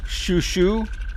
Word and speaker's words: Xiuxiu: Right now we're listening to Xiuxiu. That's spelled X-I-U-X-I-U, so it would be the Chinese pronunciation, which is not quite a sh sound, like a sh, sh.